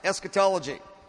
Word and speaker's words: eschatology: Eschatology. Look to your neighbor and say, eschatology. Say, okay, now I know how to say it.